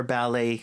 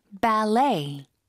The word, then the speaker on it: ballet: Ballet. – Ballet. – Ballet. – 뒤쪽에 힘을 주는 단어.